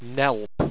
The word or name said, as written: nelpe